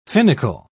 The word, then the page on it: finical: British English